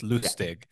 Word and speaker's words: lustig: Uh his last name is spelled the same way as the German word for funny. It's pronounced lustig.